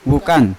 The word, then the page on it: vukan: Yawa